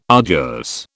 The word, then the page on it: arduous: British English